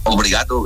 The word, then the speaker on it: Obrigado: ¿Cómo, obligado, sí, ¿cómo se dice? Obligado es gracia, ¿no? – Obrigado, gracias.